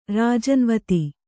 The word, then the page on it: ರಾಜನ್ವತಿ: Kannada